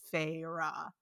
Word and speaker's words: Feyre: like dragged it out into two syllables, and they spell out the two syllables, so it's Feyre.